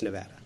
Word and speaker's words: Nevada: It's Nevada. – Why? – Because that's how they say it in Nevada.